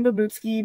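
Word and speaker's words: Babutzke: So yes, Babs comes from my last name Babutsky, because when I got married, nobody could pronounce my name.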